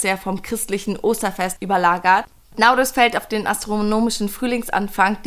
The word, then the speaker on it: Nauruz: Genau, es geht ja heute um Naurus oder Nevrus. Es gibt verschiedene Aussprachen. Ich sag Naurus, Naurus ist tatsächlich das älteste Fest, was auf der Welt gefeiert wird.